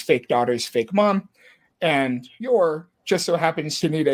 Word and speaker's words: Yor: And then another convoluted set of circumstances make it so that both Lloyd and Yor, it is Yor, right? Or is it? – It is. It's, yeah. I mean, it's written Yoru, but the author also insists that it is spelled Yor.